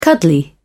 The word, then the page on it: cuddly: British English